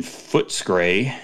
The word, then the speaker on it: Footscray: Nick hit brewery number 111 of 135 at Hop Nation in Footscray.